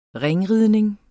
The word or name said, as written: ringridning